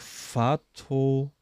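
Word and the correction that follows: Fathollahi: Also F-A-T-H-O-L-A-H-I. Safe einfach komplett falsch ausgesprochen. Ich hätte viel machen müssen, glaube ich. ähm, oh, ja, spricht eine Sprachnachricht ein und die schneide ich jetzt hier ein, damit ihr hört, wie er richtig ausgesprochen wird. Also, du wirst mich auslachen, aber ja, also da heißt Babak Fatuhlahi.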